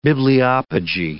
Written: bibliopegy

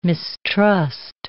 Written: mistrust

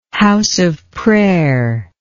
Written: house of prayer

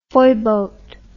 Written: foyboat

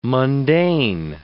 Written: mundane

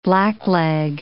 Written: blackleg

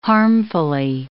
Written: harmfully

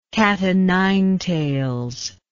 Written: cat-o'-nine-tails

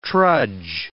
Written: trudge

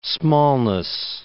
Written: smallness

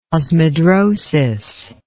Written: osmidrosis

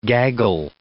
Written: gaggle

